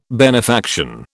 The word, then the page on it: benefaction: British English